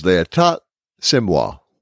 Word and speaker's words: L'État, c'est moi: Now, Brooking expresses the opinion, the Republican Party platform is l'état, c'est moi. Pardon my French pronunciation. – Oh, that was fine. – It was good.